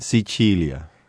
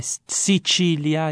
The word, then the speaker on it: Sicilia: How do you say Sicily in Italian? – Sicilia. – Yes, this is the name in Italian that we say Sicilia.